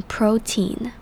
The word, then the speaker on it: protein: 接着我们来看 protein 这个字，第一个要注意的重点是它的重音在第一个音节，也就是 PRO 这边的音节，所以是念成 protein。接着我们要看 PRO 的 O 是长母音 O 的声音，而 protein 后面的 EI 则是发成长母音 E 的发音，所以整个字是 protein。